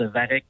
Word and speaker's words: Varech: Oui, en fait, pour la précision technique, on prononce Varech, donc c'est l'algue, une algue qu'on retrouve sur le long des berges, notamment du Saint-Laurent.